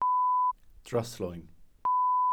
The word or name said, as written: dryslwyn